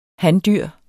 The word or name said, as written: handyr